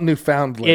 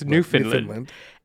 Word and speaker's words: Newfoundland: And there were 7,000 passengers stranded in a tiny little Canadian town, Newfoundland. – I know, it's spelled Newfoundland. – It is, but it's Newfoundland.